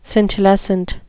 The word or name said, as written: scintillescent